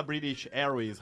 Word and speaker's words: British Airways: Que como é que fala British Airways? – British Airways.